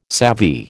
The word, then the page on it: savvy: American English